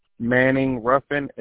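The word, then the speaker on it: Manning-Ruffin: O-N-C-A. – And what's the last name? – Manning Ruffin, M-A-N-N-I-N-G, hyphen, R-U-F-F-I-N.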